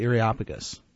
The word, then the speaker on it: Areopagus: Take, for example, when Paul makes his speech at the Areopagus. I know I just said that wrong. – No, that's right. – Okay. – That's right. That's the way I say